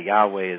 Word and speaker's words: Yahweh: So Psalm 146, by the way, Yahweh is what many people think is the correct way to pronounce God or Elohim's real name.